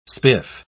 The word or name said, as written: spiff